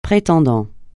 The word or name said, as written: prétendant